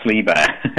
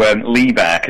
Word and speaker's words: Liebert: So Liebherr, because this is the main thing people ask when they ask about my music. It's spelt Liebert with a T on the end, but it's a silent T, because I think it's Belgian, but apparently it's a really common name in Belgium and France, but not in the UK.